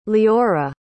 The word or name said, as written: leora